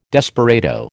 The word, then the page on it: desperado: American English